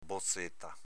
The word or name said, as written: bozeta